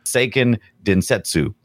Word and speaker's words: Seiken Densetsu: In Japan, it was known as, I'm going to butcher this, Seiken Densetsu.